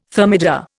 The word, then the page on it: thermidor: Indian